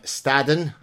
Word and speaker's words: Staddon: Hey guys, delighted today to have with me Professor John Staddon.